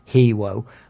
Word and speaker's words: Hero: In space, you had Hero, pronounced Hewo, and the uber stereotype gay Zeroid 101, who made Mr. Humphries seem like Jeremy Clarkson.